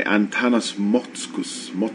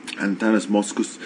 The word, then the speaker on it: Antanas Mockus: It was organized mainly by Antanas Mockus, that's how you pronounce his name in Lithuanian, because he's of Lithuanian descent, the former, I guess several mayors ago, but the former mayor of Bogota, several time presidential hopeful, Antanas Mockus.